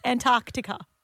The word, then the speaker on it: Antarctica: Are you supposed to pronounce the C in Antarctic? – Yes. – Antarctica.